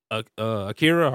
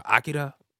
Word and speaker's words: Akira: Akira or Akira, if you pronounce it correctly. – Akira.